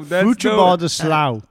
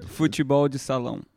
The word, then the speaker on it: Futebol de Salão: Did I say that right? – No. – Futebol do Salão. – Yes, come that on. Was better. – Tell the audience how it should be said.